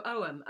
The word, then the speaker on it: Ogham: Oum. O-G-H-A-M. – Oum. Oh, yes, thank you. Seen it spelt, but never pronounced. – Yeah. So Oum, much like the word omen, I guess.